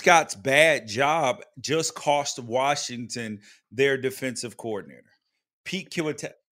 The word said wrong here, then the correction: Kwiatkowski: Kwiatkowski. I think that's how you pronounce his name. – Pete Kwiatkowski. We'll say yeah.